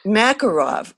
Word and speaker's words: Makarov: That was Makarov, correct? – Makarov. – Okay. – Okay, right. And I'm sorry, I mispronounced it, but it said that is the Russian military and police weapon, semi-automatic, okay, for decades.